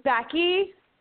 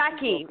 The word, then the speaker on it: Becky: Becky. – Jackie. Well, Jackie. – Becky. – Welcome to the- Becky. Hi. Becky. – Jackie, spell it. – B-E-C-K-Y.